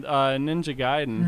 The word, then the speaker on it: Ninja Gaiden: We talked about this with Ninja Gaiden.